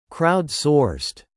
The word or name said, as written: crowd-sourced